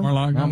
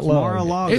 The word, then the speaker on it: Mar-a-Lago: Is it Mar-a-Lago? – Mar-a-Lago. – Not Mar-a-Lago.